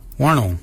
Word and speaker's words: wondil: So for shoulder, we say wano. Wano is your shoulder. Wano is spelled W-O-N-D-I-L.